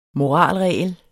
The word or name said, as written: moralregel